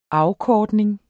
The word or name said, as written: afkortning